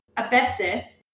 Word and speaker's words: ABC: Okay, so Enjolras, he is leader of the student rebel group called Les Amis de Abyssé, which is a pun on Abyssé. So it's spelled ABC, but because ABC is pronounced Abyssé, and Abyssé, spelled in a different way, means the wretched.